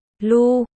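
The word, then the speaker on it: Lugh: Anh Sáp, Lắc phát âm là Lu, là người anh hùng vùng Celtic hay còn gọi là thần mặt trời.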